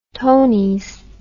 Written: tonys

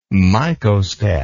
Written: mycostat

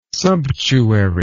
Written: sumptuary

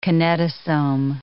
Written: kinetosome

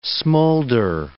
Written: smoulder

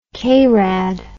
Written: krad

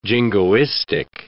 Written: jingoistic

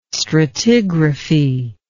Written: stratigraphy

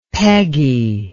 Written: Peggy

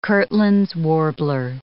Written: Kirtland's warbler

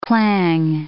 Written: clang

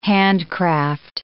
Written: handcraft

